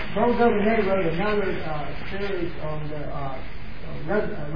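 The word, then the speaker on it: d'Aubigné: Merle Daubigny, especially with the southern pronunciation of a French name. J.H. Merle Daubigny.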